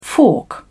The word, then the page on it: fork: British English